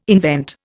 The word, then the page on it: invent: Indian